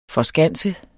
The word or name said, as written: forskanse